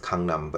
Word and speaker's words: Gangnam: I was, you guys know the song Gangnam Style, right? It's supposed to be pronounced Gangnam, but anyways, I was actually standing right there in the middle of the largest district road in Gangnam.